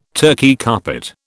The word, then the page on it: Turkey carpet: British English